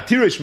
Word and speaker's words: tirosh: Now, tirosh means wine. It's written chaser, reish shin, but it's pronounced as if there would have been a vav there, right? This takes us back to Sanhedrin daf dalet, yeshem lemikri, yeshem lemesayers. So it sounds like there's a vav.